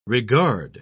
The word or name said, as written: regard